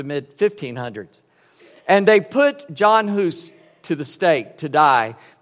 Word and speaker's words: Hus: 100 years before him was a man named John, and it's pronounced Hus, H-U-S. It's been anglicized into Huss, H-U-S-S.